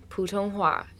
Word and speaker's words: 普通话: So pretty much everybody who speaks Mandarin, 普通话 is how you say it in Chinese,